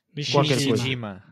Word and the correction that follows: Nishijima: Pronto, Nishijima, é pronto, é isto.